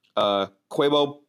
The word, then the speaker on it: Cobus: We had Esther Feminko, and that is E-S-T-E-R. And then last name, F-O-M-E-N-K-O. Nate Ernsberger, and that's E-R-N-S-B-E-R-G-E-R. Quebo Potgeiter, and that's C-O-B-U-S. So it's spelled Cobus, which sounds a lot less cool. And his last name is Potgieter, P-O-T-G-I-E-T-E-R, David Greco, and that's G-R-E-C-O, so only one C, and then Dustin Tavella, and that is T-A-V-E-L-L-A, and this is Brave Work.